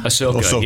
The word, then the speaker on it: Ahsoka: Ahsoka.